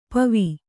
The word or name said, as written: ಪೂವು